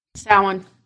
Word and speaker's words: Samhain: Samhain.